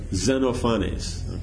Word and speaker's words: Xenophanes: Xenophanes.